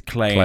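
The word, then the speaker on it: Klei: Do you say Klee? Clay? – Oh, I don't know. All I know is that all of their games are good. – I think it's Clay Entertainment. – I think it rhymes with play.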